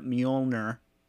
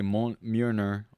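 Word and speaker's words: Mjölnir: Captain America lifts Thor's hammer Mjolnir scene. – That was really good pronunciation. I say Mjolnir.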